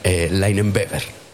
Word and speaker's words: Leinenweber: Es más alemán. Leinenweber.